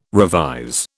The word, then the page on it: revise: British English